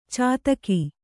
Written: ಚಾತಕಿ

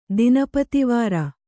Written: ದಿನಪತಿವಾರ

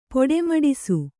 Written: ಪೊಡೆಮಡಿಸು

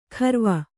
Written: ಖರ್ವ

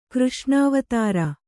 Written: ಕೃಷ್ಣಾವತಾರ

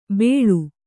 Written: ಬೆಳು

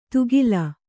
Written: ತುಗಿಲ

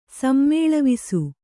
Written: ಸಮ್ಮೇಳವಿಸು

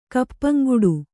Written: ಕಪ್ಪಂಗುಡು

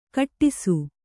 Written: ಘಟ್ಟಿಸು

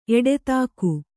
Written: ಎಡತಾಕು

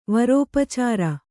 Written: ವರೋಪಚಾರ

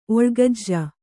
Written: ಒಳ್ಗಜ್ಜ

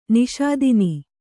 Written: ನಿಷೂದನ

